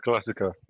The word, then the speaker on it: Klassiker: Well, obviously, the first place to start is Mr. Bayern himself about the Klassiker, right? Did I say that right? Is the Klassiker? Is it the Klassiker? – Yeah. The Klassiker.